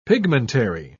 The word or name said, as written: pigmentary